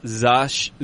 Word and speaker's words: Zasha: It's pronounced Zasha. All right, so it's like Sasha with a Z.